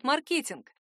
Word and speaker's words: маркетинг: В свою очередь, правы и те, кто отстаивает маркетинг. Дело в том, что русскому языку свойственно стремление к ритмическому равновесию. Оно означает, что русские слова плохо переносят большое количество заударных слогов. Нам просто неудобно произносить такой длинный безударный хвост. Маркетингу, маркетингом, маркетинга, маркетинговый. Именно поэтому и произошло смещение.